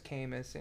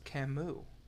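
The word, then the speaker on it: Caymus: Camus. And he goes, actually, it's pronounced Camus. – What? – After the writer.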